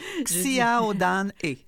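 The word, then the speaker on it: Xiaodan He: C'est un film réalisé par, comment on prononce? Xiao Dan E. – Oui.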